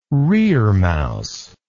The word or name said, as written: reremouse